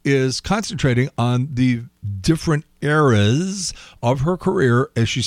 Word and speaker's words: Eras: They're saying Eras and variations of Eras. And it's not. It's Eras, as in the plural of era.